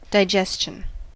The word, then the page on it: digestion: American English